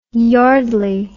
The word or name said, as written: yardley